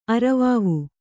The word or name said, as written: ಅರವಾವು